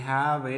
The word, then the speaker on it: have: Vamos a enlazar este have it acá de una vez. La E no se pronuncia.